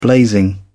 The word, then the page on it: blazing: British English